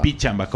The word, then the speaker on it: Pichamba: Pichamba, con P se escribe, pero se dice con F, así como Filco. Filco.